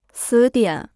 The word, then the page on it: 词典: cí diǎn